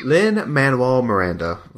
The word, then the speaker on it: Lin-Manuel Miranda: Lin-Manuel Miranda, right? Am I getting his name right? – Yes, you are. Yes.